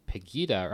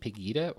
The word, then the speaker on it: Pegida: Yeah, and this event was actually co-organized, I believe, with Pegida. Or I don't actually know, is it Pegida or Pegida? – I feel hard G, just because that's my experience with the German language.